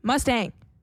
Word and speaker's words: Mustang: Ça dit ici, la deuxième syllabe doit rimer avec sang or bang. Mustang.